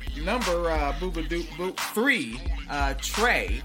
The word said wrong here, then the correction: Trace: Not good at pronouncing that one. – Trace. – Yeah, there's an S at the end. I'm so hesitant on the S. Trace.